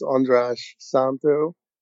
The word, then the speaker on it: András Szántó: It's András Szántó. If that sounds a bit odd, it's a Hungarian name. actually, in Hungarian, you're supposed to say it the other way around, but let's not get into linguistics.